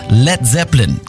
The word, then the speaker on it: Led Zeppelin: Led Zeppelin. Das A in Led ließ er einfach weg, damit es nicht wie Blei ausgesprochen wird.